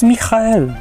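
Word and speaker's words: Michael (מיכאל): W oryginale jest Michael, tak, przez to twarde H, Michael. Naprawdę się to tak wymawia, ja się nie wygupiam z tym H. To się tak mówi, harcząco.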